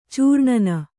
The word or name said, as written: ಚೂರ್ಣನ